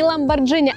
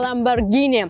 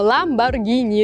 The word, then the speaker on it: Lamborghini: Еще одна марка машины, которую многие люди называют неправильно, говорят Lamborghini. Так вот, нет, не Lamborghini, а Lamborghini. Согласно правилам итальянского языка, если после G стоит ака, это H в английском варианте, то эта буква не читается и остается только G, поэтому Lamborghini, ребята.